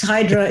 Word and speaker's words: Hydra: It is Idra if you live there. It's Hydra if you're a tourist.